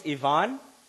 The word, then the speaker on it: Ivan: My name is Ivan, spelled like Ivan, but just pronounced differently.